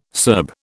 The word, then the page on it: Serb: British English